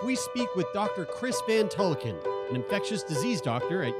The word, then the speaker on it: Van Tulleken: Yeah, we are sitting down with Dr. Chris Van Tulleken.